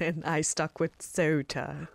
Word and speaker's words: Ceuta: It's spelt, if you want to look for it online, Ceuta. The people on the Spanish mainland, they probably pronounce it something like Ceuta. Oh. But my Spanish pronunciation isn't that good. And in England, we say Ceuta. So that's what I'll say.